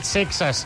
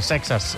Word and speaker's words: Seixas: Sexes, que és com ens diu l'Isaac Vilalta, que l'hem de pronunciar. Sexes.